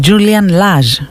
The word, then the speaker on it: Julian Lage: Και λέω να κλείσω με κιθαρίστα πάλι, αλλά αυτή τη φορά με ένα, πραγματικά είναι ένα θαύμα της φύσης αυτός ο Julian Lage, συγγνώμη, Julian Lage, έτσι προφέρεται, αυτός ο τόσο ωραίας αισθητικής τύπος, που παίζει και με την ηλεκτρική και με την ακουστική.